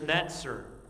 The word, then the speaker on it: Netzer: Now the word branch here is Netzer. N-E-S-E-R is how we would spell it, but it's pronounced N-E-T-Z-E-R, Netzer.